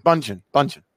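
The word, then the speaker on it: Bündchen: Isn't it Munchin? – I think it's with a B. – I think it's Bunchin. – Bunchin. – That's how you say that? – Yeah, Bunchin. – Oh, silent D.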